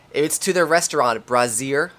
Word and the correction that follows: Brasserie: I believe that's how you pronounce it. Brasier. – Brasserie.